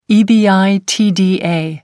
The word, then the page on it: ebitda: American English